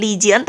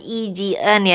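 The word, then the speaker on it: I Jian (이지은): Congrats buat Lily Couple yang sebenarnya bacanya bukan Lee Jong Sok, bukan Lee Jian tapi I Jong Sok dan I Jian ya.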